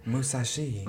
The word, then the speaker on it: Musashi: Musashi.